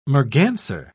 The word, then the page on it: merganser: British English